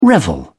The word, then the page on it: revel: British English